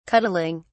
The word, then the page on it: cuddling: British English